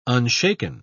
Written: unshaken

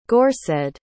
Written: gorsedd

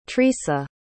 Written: trisa

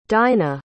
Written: dynah

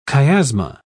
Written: chiasma

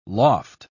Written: loft

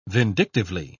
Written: vindictively

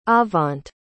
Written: avant

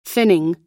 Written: thinning

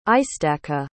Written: istaqa

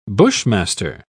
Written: bushmaster

